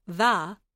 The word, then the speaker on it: thou: Let's take a close look at how Dickon, Martha, and Ben Weatherstaff all use pronouns such as thou, which is pronounced tha in Yorkshire, thee, thy, thysel, meaning thyself or yourself, we, and us.